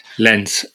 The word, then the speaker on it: Lens: L-E-N-S. – No, I think it's French, isn't it? So I think it's Lens. – Oh, you're going for the French. Oh, my bad. I didn't know you were catering to our French audience. Yes, like my friend. – Nantes. – I love that. – Like Nantes is Nantes, isn't it? Like, Nantes, yeah. To be fair, can we just say what it is? Can we just read it phonetically? Lens. – Yes, it's Lens. It's Lens. Lens.